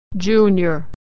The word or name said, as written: junior